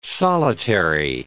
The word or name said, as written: solitary